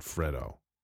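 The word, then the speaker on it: Fredo: Now I have to pause and make sure that I'm pronouncing the name right. 1 second. Okay, careful watching of a YouTube video suggests that Pacino really pronounces it Freddo. He kind of muffs the E a little bit there in the middle. We'll go with Freddo.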